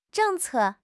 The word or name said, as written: 政策